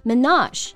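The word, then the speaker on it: Minaj: 其实比较简单啊，在 Manar 后面发一个很轻的 sh 这个音就可以了，然后重音呢在 not 上面 Menage。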